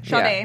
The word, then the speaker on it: Sade: Sade. – If it's like the singer, Sade.